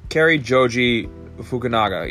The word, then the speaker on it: Cary Joji Fukunaga: I don't want to butcher his last name, but I think it's Car- Cary Koji, oh, Cary Joji Fukunaga, yeah.